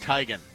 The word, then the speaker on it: Teigen: Her name apparently is supposed to be pronounced Teigen. – Oh. – And she goes with Teigen, but everyone pronounced it wrong, so she said, just go with it.